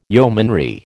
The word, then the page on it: yeomanry: American English